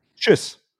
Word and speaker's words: tschüss: Or when you will do it shortly, like the Italian ciao, it's tschüss.